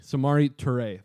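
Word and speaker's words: Samori Toure: Moving on, Sammy Toure. Sammy, I didn't pronounce that correctly. – No, Samari. – Samari Toure. There we go.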